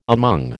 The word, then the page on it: among: American English